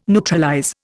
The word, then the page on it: neutralize: Indian